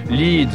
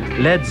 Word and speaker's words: Led: La transformation de Led, L-E-A-D, en Led, L-E-D, revenant à Peter Grant, parce que les Américains, pensent-ils, auraient tout de suite prononcé Lead, comme eux, les Anglais, la ville, alors on écrira Led Zeppelin pour que les Américains prononcent comme dans Led Guitar, celle qui conduit.